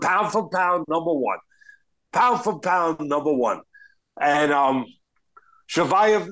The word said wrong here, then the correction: Chimaev: Chimaev, excuse me, might be number one now.